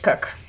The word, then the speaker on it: Koch: Yeah, Claire Cook, K-O-C-H Cook. Why is it pronounced like that?